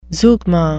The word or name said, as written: zeugma